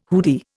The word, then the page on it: hoodie: Indian